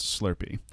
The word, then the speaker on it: SLRP: Like, it's spelled S-L-R-P, but pronounced Slurpee.